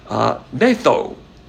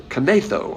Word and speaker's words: knetho: The word ears tickled is spelled K-N-E-T-H-O, pronounced netho. With kanetho, I guess, if you pronounce the K.